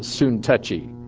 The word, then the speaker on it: Syntyche: Some pronounce it Soon-touchy, because she was so touchy.